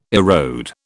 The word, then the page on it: erode: British English